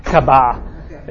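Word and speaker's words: Kaaba: It's called the kavah, K-A-B-A, kavah is how they pronounce it.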